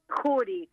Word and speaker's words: Jury: ¿Tu apellido es Jury, como lo pronuncié, o Yuri? – Jury, como lo pronunciaste.